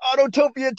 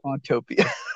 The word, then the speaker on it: Autopia: Autopia. – You don't like Autopia. – Oh, you called it Autotopia too.